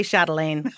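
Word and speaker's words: Châtelaine: She's a chef, a teacher, the former food director at Châtelain magazine. Am I pronouncing that correctly? – You could just say Chatelaine.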